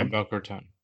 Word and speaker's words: Boca Raton: Yeah, Boca Raton.